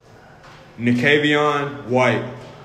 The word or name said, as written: Nakavieon White